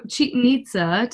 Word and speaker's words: Chichen Itza: I don't know if it's pronounced Chichen Itza, but it sounds good to me. – It is because I looked it up.